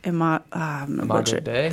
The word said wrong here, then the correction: Imago Dei: Imagio Dei. – I've butchered it. – Imago Dei. – One of these days, I'm going to learn actually how to say some of these words. – I-M-A-G-O. Imago Dei. Yeah.